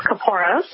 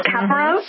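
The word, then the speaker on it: kaporos: Well, there are several pronunciations. We tend to stick to the pronunciation of caporos, but you might hear others refer to it as caparos or caporos. So you'll hear several incarnations depending on who you speak with, but we tend to just say caporos.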